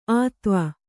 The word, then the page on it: ಆತ್ವ: ātva